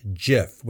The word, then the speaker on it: GIF: It's pronounced GIF with a soft G, like Jiffy peanut butter, and not a hard G like gift.